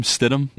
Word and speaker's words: Stidham: Stidham? – Yeah.